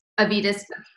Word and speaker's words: Avitas: She is also the founder, we're going to have to cut because Avitas Village Community, is that how you pronounce it? – Yes. – Avitas. – Okay.